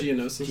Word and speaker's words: Geonosis: Geonosis.